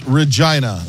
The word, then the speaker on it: Regina: It's Regina. That's what? The way it's pronounced.